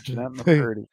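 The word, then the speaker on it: Jennette McCurdy: Jeanette McCurdy. Wow. Am I saying that right? – Oh, Yep. Jeanette McCurdy.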